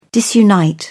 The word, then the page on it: disunite: British English